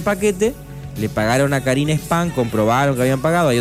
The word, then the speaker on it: Spahn: Karina Spam. Spam se escribe S-P-A-H-N.